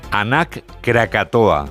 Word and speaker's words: Anak Krakatoa: Nos vamos a Indonesia, donde la erupción del volcán, vamos a ver si lo decimos bien, Anak Krakatoa. – ¿Lo he dicho bien? – Sí, parece euskera, pero es indonesio.